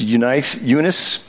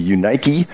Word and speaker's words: Eunice: we would pronounce this Eunice, at the actual Greek it would be Eunike, I am persuaded that in thee also, unfeigned believing.